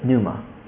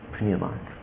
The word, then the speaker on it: Pneuma: And so we decided to start a once-a-month outreach event, and we called it Pneuma. で、この一人の日本人と出会ったきっかけを通して、月に一回、他のシカゴにいる日本人の大学生に福音を伝えるイベントを始めました。で、それを Pneuma という名前をつけました。 The name came from the word pneumatology, but we took the ology part out and we pronounced the P. So it's Pneuma, Pneuma.